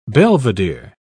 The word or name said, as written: belvedere